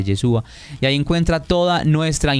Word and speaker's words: Yeshúa: Suena así, sería como Yeshúa, pero es Yeshúa.